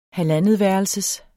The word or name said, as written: halvandetværelses